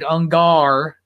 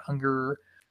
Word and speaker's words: Ungar: Give it a listen to me and Big Rick and David Ungar, not Unger. Apparently I spelled it and pronounced it wrong.